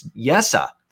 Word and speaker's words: Jesse: So Jesse, and that's how you pronounce it. It's not Jesse. It's not Jesse. It's Jesse. That's how you actually pronounce this name, Puljujärvi, for the Edmonton Oilers.